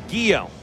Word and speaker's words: Gio: When you write it phonetically, first name, Gio. G-H-E-E, and then the dash, Yo. Y-O. And then the last name, Zuniga. Zun, Z-O-O-N. And then Y-E-E, here's the pitch, popped up first base side out of play, 1-2.